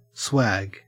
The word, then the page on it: swag: Australian English